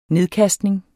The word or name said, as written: nedkastning